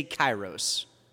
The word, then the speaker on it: kairos: which we would call kairos. Say kairos.